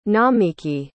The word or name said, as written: nomiki